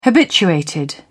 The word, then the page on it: habituated: British English